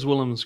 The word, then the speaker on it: Willems: Williams team. Am I pronouncing that correctly? – Verandas Willems Crelan, I think.